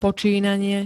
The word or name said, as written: počínanie